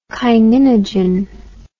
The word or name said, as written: kininogen